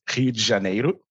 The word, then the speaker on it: Rio de Janeiro: So I'm original from Brazil, Rio, especially, or for everyone who speaks Portuguese, Rio de Janeiro. and that's the way we pronounce.